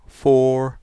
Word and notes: Four: American English

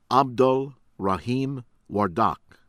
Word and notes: Abdul Rahim Wardak: American English

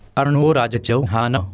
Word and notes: अर्णोराज चौहान: /ˈərnoʊrɑːjə xɔːhɑːnə/

